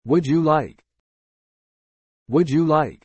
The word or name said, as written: Would you like